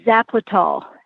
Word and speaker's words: Zapletal: My last name is Zapletal. – Zapletal. Okay. – It's spelled exactly the way it sounds. Z-A-P-L-E-T-A-L. Zapletal.